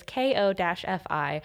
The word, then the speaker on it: Ko-fi: Daniel set up this awesome Ko-fi, I don't know how to say it. It's Ko-fi.